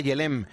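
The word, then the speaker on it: Gelem: Ese es el Yelem que realmente se escribe con G, Yelem, pero que se pronuncia Yelem.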